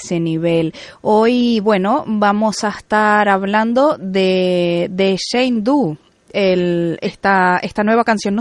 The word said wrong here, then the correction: Jane Doe: Bueno, sí, Jane Doe.